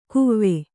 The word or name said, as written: ಕುವೆ